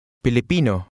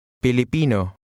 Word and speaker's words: Filipino: Here's how to say Filipino. Listen and repeat. – Filipino. Pino. Pili. Filipino. Filipino. – How would a man say, I'm Filipino? – Filipino ako.